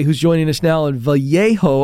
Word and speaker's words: Vallejo: The city's name is Vallejo, sir. – Vallejo.